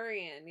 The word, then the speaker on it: durian: So for people listening, if you don't know what is it durian? Is that how you say it? – Durian, yeah.